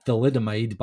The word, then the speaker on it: thalidomide: They had thalidomide back in the day. Is it thalidomide? I'm saying that right? – I believe so.